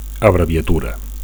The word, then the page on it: abreviatura: Catalan